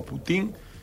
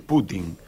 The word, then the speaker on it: Putin: Sí, bueno, eh, normalmente los argentinos tendemos a acentuar la última sílaba en los nombres rusos y, muchas veces al revés, como si decimos Putin y es Putin.